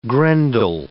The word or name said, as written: Grendel